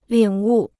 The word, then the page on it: 领悟: lǐng wù